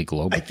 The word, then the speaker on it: Glob: I don't know if I said that right. Glob. I'm sure it means something in Russian, probably globe. – I think it is pronounced globe as well. So you nailed it.